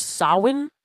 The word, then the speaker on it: Samhain: Which I didn't think that was a correct pronunciation, because this thing is spelled, S-A-M-H-A-I-N. So for an M to make a W sound, it was like, what's going on?